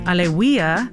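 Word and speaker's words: Alewya: Back-to-back new tunes in the set with Gutta by Alewa. Alewia, there we go. I finally learned how to say it right.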